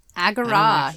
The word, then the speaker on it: Agora: Agora, yes.